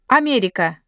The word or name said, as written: America